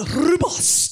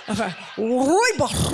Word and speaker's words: rooibos: One of my favorite herbal teas is the rooibos. Did I say it right, all the South Africans? Oh, okay, I failed. Rooibos, right, you got to roll the R.